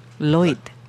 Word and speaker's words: Lloyd: Lloyd.